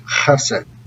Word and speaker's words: chesed: My favorite word in all of Holy Scripture is the Hebrew word chesed. It's pronounced with that guttural sound, and it's such a rich word that no single English word can capture its depth. So chesed